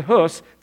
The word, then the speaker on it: Hus: Go with me to Czechoslovakia, and there's a gentleman there that you would call his name John Huss, but properly pronounced John Hus, and begins to see the reality in only the way that Martin Luther would explain it 100 years from this time.